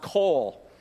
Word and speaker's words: qowl: The extra Hebrew word, if you want to write this down, the key word, it's spelled Q-O-W-L. Great Scrabble word, especially for a triple word score. Q-O-W-L. It's pronounced coal.